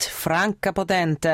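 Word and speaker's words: Franka Potente: Also wenn mich einer fragt zum Beispiel, wie spricht man deinen Namen aus, dann sage ich immer wie ein italienisches Gericht, Franca Potenti.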